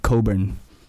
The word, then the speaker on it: Cockburn: Or even, you know, going, if you take the freeway down south and you see like Coburn. – It's Cockburn.